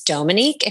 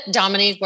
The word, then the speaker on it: Dominique: Yeah, well, technically it's Dominique if we want to split hairs. My mom is French, so it's Dominique, but Dominique works.